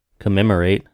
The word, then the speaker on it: commemorate: Nope. Correminent. Words I cannot pronounce. – To commemorate. – Commemorate.